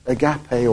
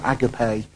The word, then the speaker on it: agape: And the main one that was used is the God kind of love, which is the agape or agape, depending on how you want to pronounce it.